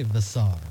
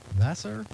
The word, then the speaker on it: Vassar: Phil, I'm going to say Vassar. Vassar? Vassa. Like the German word for water, just Vassa. Phil Vassar. I'm going to pronounce it that way.